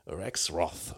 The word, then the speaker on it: Rexroth: Wir hatten mal einen Wirtschaftsminister, der Rexroth hieß, aber dieser Mann äh, wird dann amerikanisch Rexroth ausgesprochen, mit dem Marriage Blues von 1960.